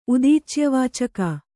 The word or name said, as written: ಉದೀಚ್ಯವಾಚಕ